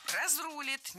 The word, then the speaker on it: разрулит: Он говорил, что Всевышний все, что называется, разрулит. Такое вот просторечие в одном предложении со словом «Всевышний». – Разрулит, неверно, правильно, разрулит, разрулим, разрулишь. Опять же, ударение ставим за корнем.